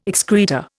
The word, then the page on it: excreta: Indian